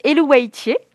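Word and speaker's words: Eluveitie: Alors moi, je le prononce Elouaitier.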